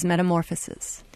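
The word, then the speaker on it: metamorphosis (μεταμόρφωση): And Rupa, I'd like you to talk about one other song off of your album, which is Metamorphosis. – Metamorphosis is a song about change, which I learned in Greek how to say it. It's metamorphosis.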